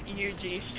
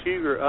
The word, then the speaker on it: Huger: I grew up here, and it was Huger up until I was about probably 10 or 12. They changed the pronunciation, but not the spelling of it. So we still call it Huger. Other folks call it UG.